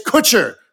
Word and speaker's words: Kutcher: And Ashton Kutcher. It's Kutcher. Rhymes with butcher.